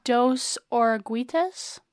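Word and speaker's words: Dos Oruguitas: Do you know how to pronounce this? – Dos Oraguitas? – That sounds right.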